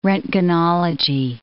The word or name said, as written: roentgenology